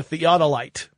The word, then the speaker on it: theodolite: Originally, I watched the video just so I could figure out how to say theodolite properly instead of saying it like theodolite or something.